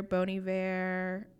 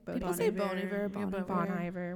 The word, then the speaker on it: Bon Iver: So the big ones, like Record of the Year, Bon Iver, or is that how you say his name? – Bon Iver, whatever.